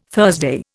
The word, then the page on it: Thursday: Indian